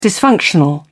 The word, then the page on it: dysfunctional: British English